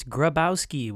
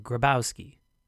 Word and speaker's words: Grabowski: It's Grabowski with a G, a big old G up front. Adam Grabowski, Grabowski, Grabowski? Why is it taking me this long to figure out how to say my name? Grabowski. I think in Polish it's Grabowska, but it's not spelled that way, so we go phonetical.